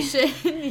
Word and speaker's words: fruition: Fruitation the other day. – Fruition, yeah.